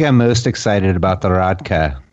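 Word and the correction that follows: rakija: at Belgrade. – Rakia, yes. – Rakia, yes. I always say it wrong.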